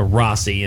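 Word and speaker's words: Rhossi: I should have probably shortened that out to Rossi and not made Rossi.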